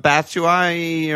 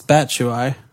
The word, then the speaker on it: Batshuayi: But Batshuayi, or what? I don't know how to say his name. Bats. You. What? – Bats. I think it's Batshuayi. – Batshuayi.